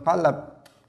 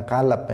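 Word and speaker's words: qalab (قلب): Nah, ketika qalab itu sering berubah-ubah, maka dia disebut qalab dalam bahasa Arab. Hmm. Bukan kalab ya. Kalau kalab itu nanti sudah beda artinya anjing.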